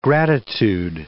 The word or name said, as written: gratitude